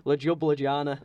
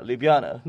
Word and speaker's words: Ljubljana: I had to ask you how to pronounce this. I feel stupid. Ljubljana. – No, Ljubljana.